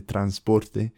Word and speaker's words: transporte: So the N is hardly pronounced. So we'd say like transport, they say almost like transporte.